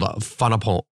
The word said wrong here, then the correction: Fanompoana: which is Fanompoana. – Fanompoana. Yeah, I'm definitely butchering that.